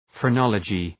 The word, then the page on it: phrenology: frı’nɒlədʒı